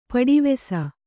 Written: ಪಡಿವೆಸ

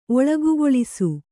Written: ಒಳಗುಗೊಳಿಸು

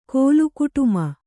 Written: ಕೋಲುಕುಟುಮ

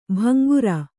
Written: ಭಂಗುರ

